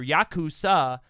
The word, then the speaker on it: Yakuza: But if the player specifically draws an 8, a 9, and a 3, which is pronounced altogether Yakuza, the sum is 20, and the score is 0, which means it's the worst possible hand that can be drawn.